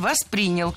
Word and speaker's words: воспринял: Было сказано «воспринял», но это неверно. Правильный вариант – «воспринял». Ударение в глаголе ставим на второй слог – «воспринял».